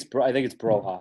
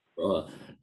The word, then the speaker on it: Borja: Broha, I think, is – am I saying that right? Or Borja? – I think it's Broha. – Yeah. – Broha.